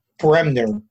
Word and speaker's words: Bremner: Bremner, B-R-E-M-N-E-R.